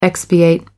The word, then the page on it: expiate: American English